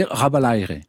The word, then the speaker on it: Rabalaire: Alors ça se dit Rabalaire, voilà.